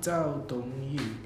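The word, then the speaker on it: Zhou Dongyu (周冬雨): Zhou Dongyu.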